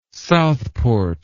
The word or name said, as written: Southport